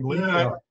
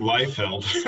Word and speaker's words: Leifeld: Leifeld. Yeah, I pronounce it Leifeld.